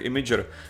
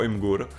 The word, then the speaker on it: Imgur: chvíli můžeš tu výslovnost určit, že jo, to máš jak to, to máš jak imager, že jo, který lidi snou soustavně, čtou jako imgur.